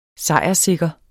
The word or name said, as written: sejrssikker